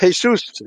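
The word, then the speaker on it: Jesús: Now, it's interesting that in the Spanish, which is one of the languages offshoot of the Anglo-Saxon, It's pronounced Jesus. It's pronounced like an H.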